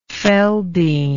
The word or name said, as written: Feldene